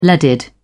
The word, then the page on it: leaded: British English